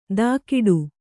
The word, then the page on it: ದಾಕಿಡು: dākiḍu